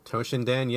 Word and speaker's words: Toshinden: The first game is Battle Arena Toshinden. I don't even know if I'm pronouncing that correctly. – Toshinden, yes.